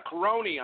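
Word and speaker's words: Cerrone: Going against the cowboy, Donald Kiroki, did I pronounce it right? Yeah, Kironi? Yeah, Kironi, I'm sorry. I'm terrible at doing this.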